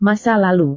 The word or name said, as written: masa lalu